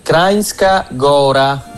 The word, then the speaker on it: Kranjska Gora: Allora, pronuncia corretta è questa. – Si pronuncia Krajinska Gora.